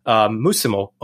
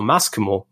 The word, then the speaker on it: muscimol: into muscimol or mascimol, both pronunciations are correct, I think, you can basically make it either edible, but it's not the tastiest mushroom, or therapeutically effective and beneficial mushroom.